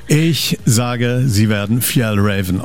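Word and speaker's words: Fjällräven: Fjell Raven. – Also Fjell Raven.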